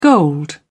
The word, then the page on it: gold: British English